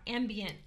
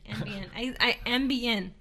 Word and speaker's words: Ambien: I know I say this wrong. Ambien. Ambien. I'm sorry. It's Ambien, not Ambien.